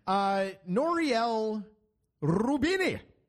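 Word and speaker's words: Nouriel Roubini: Noriel Rubini, which rhymes with Houdini.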